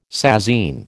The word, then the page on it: sasine: American English